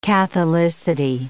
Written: catholicity

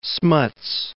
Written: Smuts

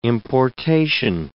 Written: importation

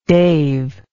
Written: dave

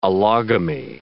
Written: allogamy

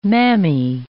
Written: mammae

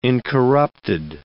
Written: incorrupted

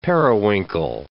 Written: periwinkle

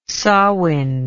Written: samain

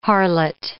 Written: harlot

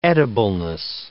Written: edibleness

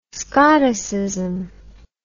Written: scoticism

